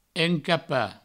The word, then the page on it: encapar: encapa